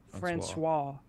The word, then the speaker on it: François: Francois. – I know. Francois.